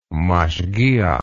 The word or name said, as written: mashgiach